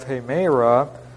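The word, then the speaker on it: hemera (ἡμέρα): And then, of course, we have the phrase here in this context, 1 Thessalonians 5, we have hemera, and that's a long E, kuriu, M-E-R-A, and then K-U-R-I-O-U, kurios, the word for Lord.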